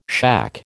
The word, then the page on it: shack: American English